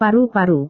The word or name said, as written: paru-paru